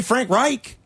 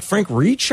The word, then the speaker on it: Reich: He didn't say Frank Reich. He called him like Frank Reach, I think, or something.